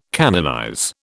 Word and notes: canonize: British English

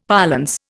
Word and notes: parlance: Indian

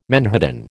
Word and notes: menhaden: American English

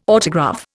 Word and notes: autograph: Indian

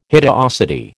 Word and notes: hideosity: American English

